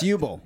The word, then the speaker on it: Uwe Boll: I think it's Uwe Boll. B-O-L-L. – U-Boll. – And he... – It's U-Boll.